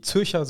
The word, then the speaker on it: Zürcher: Die Zürcher, du hast es fast schon zu gut ausgesprochen. Die Zürcher. Ich, ich, möchte weiterhin appellieren, dass wir das Deutsch aussprechen. Die Zürcher Südkurve.